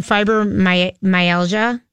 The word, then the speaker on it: fibromyalgia: She suffers from fibromyalgia. Am I saying that right? – Yes.